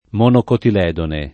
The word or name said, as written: monocotiledone